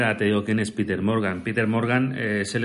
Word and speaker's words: Peter Morgan: Hemos visto 10 capítulos, que son los de la primera temporada, y el creador es Peter Morgan, ¿se dice así Peter Morgan? – Peter Morgan, no tiene mucha dificultad este bueno, este nombre.